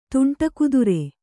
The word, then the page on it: ತುಂಟಕುದುರೆ: tuṇṭa kudure